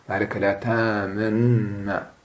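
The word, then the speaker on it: تأمنا: معناها أن تطغم النون الأولى في النون الثانية، لكنك تطبق الشفاه قبل النطق بالنون الثانية. شوف مالك لا تامنا، مالك لا تامنا، شوف مالك لا تامنا، كأنك تطبق الشفاه قبل النطق بالنون الثانية، هذا على قول.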